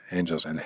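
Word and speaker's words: ángeles: So three N's, I-N-T-N-T-A-N-D-O, intentando, is trying. So our, nuestro. Before, antes. Them, a ellos. Angels, angeles. And it has the accent on the A.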